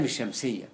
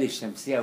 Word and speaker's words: الشمس: طيب عندنا أيضا في الإملة حاجة برضو من الحروف التي لا تنطق اللام الشمسية بتاع آل بيقولوا فيه آل الشمسية وآل القمرية حتى بيعلموها للترم زي كده القمر نطقت اللام إنما الشمس ما نطقتش اللام ما قلتش الشمس بقول الشمس اللام ده مرتها في الشين اللام الشمسية واللام القمرية فبرضو دي متصلة بالكتابة.